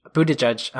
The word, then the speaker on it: Buttigieg: and Pete uh, Buttigieg, Buttigieg.